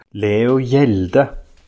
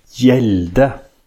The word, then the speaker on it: Hjelde: Leo Hjelde. Hjelde. Like yelling. Hjelde. – That's how you say it, Hjelde, basically.